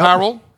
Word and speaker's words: Harrell: Harrell.